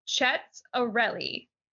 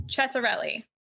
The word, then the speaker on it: Ciattarelli: So it's pronounced Chet Arelli. So it's spelled C-I-A-T-T-A-R-E-L-L-I, pronounced Chet Arelli.